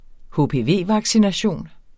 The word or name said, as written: HPV-vaccination